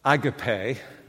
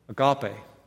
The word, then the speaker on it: agape: I would call it agape. That's how we would pronounce it. You pronounce it here in the U.S., agape.